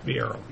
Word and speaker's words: Viero: Valero. Am I pronouncing that right? – Viero. – Viero. Viero. – There's no L in there.